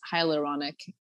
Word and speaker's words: hyaluronic: She has Ayurvedic oil products, and she has this hyaluronic.